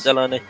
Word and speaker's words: Delaney: Acho que a pronúncia é Delaney, mas... – É Delaney.